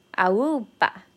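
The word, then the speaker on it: aúpa: Awupa. Awupa. And the, the intonation, um, the stress is is on 那个 u. Awupa.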